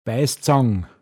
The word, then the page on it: eppan am Bandl haobm: Pinzgauer Mundart